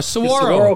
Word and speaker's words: Saguaro: ALA kicks off the season Friday, August 25th against Seguero. Am I saying that right? Seguero? – Isn't it Seguero? – Seguero. – It's Seguero. Saguaro. – Saguaro cactus.